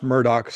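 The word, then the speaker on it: Murdaugh: Yeah, it's pronounced Murdoch, spelled Murdaw, but yeah, Murdoch.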